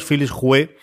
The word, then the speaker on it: Philips Hue: que en este mes de febrero son dos bombillas Philips Hue HomeKit GU10. ¿Lo he dicho bien, Pedro? – Lo has dicho bien, sí.